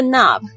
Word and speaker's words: knob: 好吧，这个 knob, K-N-O-B, 注意这个 K 不发音，在美国呢是指这个，就是我们门上的那个圆形的这个把手，那就是你可以把它锁起来或者是不锁都没有关系，那个圆圆的东西就叫 knob。